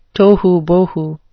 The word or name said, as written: tohubohu